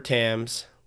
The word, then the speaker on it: Thames: But recreational camping likely has its start in the United Kingdom along the River Thames, where folks would leave, which it's not Thames. I pronounced it Thames forever, but it's River Thames.